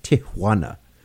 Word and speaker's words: Tijuana: I know it's Tijuana. Got to say it like that. Tijuana.